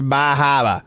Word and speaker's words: Bar Harbor: And I believe Bar Harbor is pronounced Bahaba.